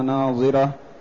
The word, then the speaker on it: ناظرة: ثم قال تعالى وجوه يومئذ ناضرة، من النضارة أي حسنة بهية مشرقة مسرورة، التي هي النضارة والجمال والبهاء والحسن، وهي تنطق بالضاد، والأخرى تنطق بالضاء التي من النظر. نعم. – أي حسنة بهية مشرقة مسرورة إلى ربها ناظرة، أي تراه عيانا، كما رواه البخاري رحمه الله تعالى في صحيحه إنكم سترون ربكم عيانا.